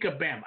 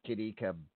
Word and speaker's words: Kabam: It's Kitty Kabam. Oh, it, there, there's there's like like yeah. Okay, so there's like a stress on the last syllable.